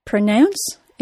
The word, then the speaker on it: pronounce: Pronounce is a two-syllable verb. Like most two-syllable verbs, it is stressed on the second syllable, pronounce. The vowel sound of the stressed syllable is spelled OU and is pronounced as the OW sound, ow. The letters OU are usually pronounced as the OW sound, so there are no surprises here. Since the second syllable is stressed, the vowel sound of the first syllable is reduced to schwa.